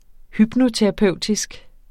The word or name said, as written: hypnoterapeutisk